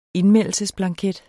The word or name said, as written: indmeldelsesblanket